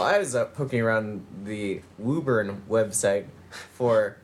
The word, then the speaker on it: Woburn: It's spelled Woburn, but it's pronounced Woburn.